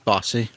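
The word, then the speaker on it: Henning Basse: Bassy? How do you say his name? – Bossy. – Bossy.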